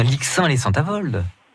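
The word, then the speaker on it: Lixing-lès-Saint-Avold: Je l'ai bien prononcé, on dit bien Lixin les Saint-Avold. – Oui, c'est ça.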